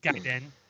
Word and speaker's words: Gaiden: Because that's how it's pronounced, Gaiden.